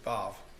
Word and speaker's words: Vav: And then we'll move on into 41, which is the letter Vav. And your Bible may have W-A-W, but Garrett assures me you pronounce it Vav.